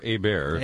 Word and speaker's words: Hébert: Lernus Hebert, or Hébert. – Hébert, yes. – Pronounce it the French way, was the guy doing the double talking there.